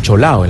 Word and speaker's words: cholado: Ajá, pero mm. correctamente se escribe cholado. – Debería escribirse así, ¿no? Pero popularmente se dice cholao.